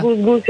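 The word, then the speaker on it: GusGus: Gus Gus, ja to zapamtim, zato je to nastalo od Kus Kus.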